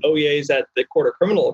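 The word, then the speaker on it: Oyez: It's not Oye or Oh yes.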